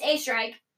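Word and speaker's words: Oesterreich: E-I-C-H. Yeah. Anyway, so. When I was younger in elementary school, everybody would say my last name wrong. And they'd either say ostrich or like Ostrike, which makes more sense than A Strike. – You would think. But it's A Strike.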